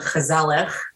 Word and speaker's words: gezellig: And this is going to sound terrible over the microphone, but there's a Dutch word called gezellig, which sounds like you're coming down with some type of flu to be able to say it correctly.